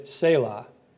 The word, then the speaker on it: Selah: And that word is Selah, S-E-L-A-H.